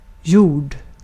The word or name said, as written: gjorda